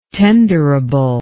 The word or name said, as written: tenderable